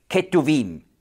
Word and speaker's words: Ketuvim: Und das ist einfach die Abkürzung T, N und Ch steht für T, Torah, Gesetz, N für Neviim, Propheten und Ch, weich ausgesprochen, weil es da ein Vokal vorher gibt, steht für Ketuvim, die Schriften.